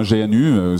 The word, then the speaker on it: GNU: GNU comme le GNU, hein, GNU, ça se prononce comme l'animal. Et donc,